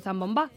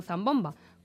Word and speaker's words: zambomba: Y sí, he dicho zambomba, que no zambomba, con acento en la A, porque así se lo inculcó su padre de pequeño.